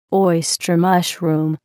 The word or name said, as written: oyster mushroom